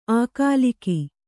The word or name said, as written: ಅಗಲಿಕೆ